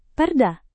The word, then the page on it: perda: Indonesian